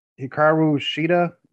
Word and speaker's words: Hikaru Shida: Hikaru Shida, did I pronounce that right? Okay. Yep.